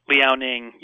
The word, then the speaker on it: Liaoning: Liaoning, yep, that's right, good.